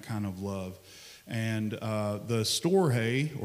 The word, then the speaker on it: storge: It's agape, phileo, I think it's storge is how you pronounce it. It's storge.